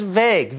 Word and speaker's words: vague: He said, No, it's not vague, you. It's just vague. V-A-G-U-E. The U-E is silent.